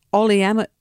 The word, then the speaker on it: oleamide: has revealed that a plastic additive called oleamide. Ole, O L E A M I D E. How would you say that? Oleamide. That's the one.